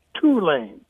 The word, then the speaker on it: Tulane: And lastly, in terms of new business, it is, in fact, pronounced Tulane, not Tulane.